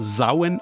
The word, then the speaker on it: Samhain: Das schreibt sich komplett anders, als es sich spricht. Samhain wird aber Sauen ausgesprochen.